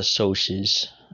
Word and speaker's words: Soc: We read the book The Outsiders, a book about greasers and what they call the soches. It's spelled, like, S-O-C, but it's pronounced soch.